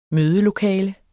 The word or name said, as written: mødelokale